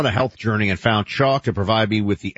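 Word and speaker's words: Choq: Chalk. That's C-H-O-Q, spelled with a Q.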